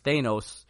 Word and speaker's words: Thanos: And so that's going to give her an edge, obviously, when she faces Thanos later. – I was like, have I been saying it wrong all this time? – No, it's Thanos.